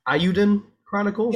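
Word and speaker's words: Eiyuden Chronicle: Is this Ayuden Chronicle? – Yeah.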